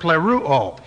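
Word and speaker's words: pleruo (πληρόω): And the word fulfill is the perfect active indicative of the word pleruo. P-L long E-R.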